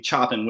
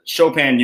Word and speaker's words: Chopin: For which the entire presentation I pronounced his name Chopin without realizing. – You know, I picked the name from a list. Get it? No, for real. I picked it from a written list, so I didn't know it was actually pronounced Chopin. And the professor was like, "Hey, great, well researched and all. It's pronounced Chopin. You get a B."